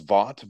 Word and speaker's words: Vaught: My last name is Vaught, but most people don't pronounce it that way when they see it spelled Vaught.